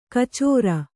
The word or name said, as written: ಕಚರಿ